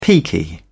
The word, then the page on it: peaky: British English